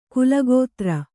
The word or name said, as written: ಕೀಲುಕುದುರೆ